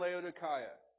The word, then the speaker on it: Laodicea: He's like, no, it's Laodicea. But in the Greek, it's pronounced Laodicea.